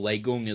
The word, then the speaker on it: Lei Kung: Um, so next point, Lei Kung or Lei Gung as the show pronounces him, the Thunderer.